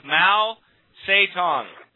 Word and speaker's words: Mao Zedong: The two men proposed using tactics on straight America that are remarkably similar to the brainwashing methods of Mao Zedong.